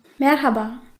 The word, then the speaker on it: Merhaba: Habe ich es einigermaßen richtig gesagt? – Ja, du hast es toll gesagt. – Sag du mal Merhaba. – Merhaba.